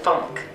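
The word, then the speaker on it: Phunk: So my alter ego's name as I perform is Etana Tanisha Funk. – Etana. – It's spelled with a P-H.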